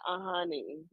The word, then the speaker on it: Ajani: So I needed to just splash that in there because it's Nigerian, it's black, and it's, you know, so that's why I pronounce it Ajani instead of how they pronounce it with the J.